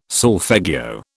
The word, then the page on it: solfeggio: British English